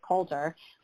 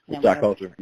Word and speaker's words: Coulter: Yeah, you know, I think that I know you had a canceled bout before your last one with Rashad, let me just pronounce his name right, Coulter. – Rashad Coulter, yeah.